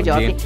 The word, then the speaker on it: Jordi: Sì, ho sbagliato il nome di Gabri Ponte e Jordi l'ho detto bene. – Gordi. – Comunque Jordi.